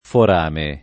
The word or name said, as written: forame